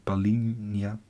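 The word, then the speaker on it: Palhinha: Pa Palinja. Palinja. Palinja. Palinja. Ja, staat LH, maar het is een J.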